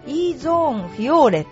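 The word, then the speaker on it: EZONE FIORE: それからですね、ヨネックス、レディースゴルファー向けのゴルフクラブ、 EZONE フィオーレシリーズを発表してます、えー、これはね EZONE っていうのは E ゾーンフィオーレって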